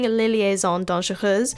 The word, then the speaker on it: Les Liaisons Dangereuses: she was in the Broadway production Les Liaisons Dangereuses. And as some of you Broadway radio listeners may know, Matt and James have had quite the journey with pronouncing Les Liaisons Dangereuses,